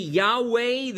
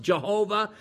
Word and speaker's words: Yahweh: The first Lord would be Yahweh, the unpronounceable name of God, YHWH. Sometimes it's mispronounced Jehovah.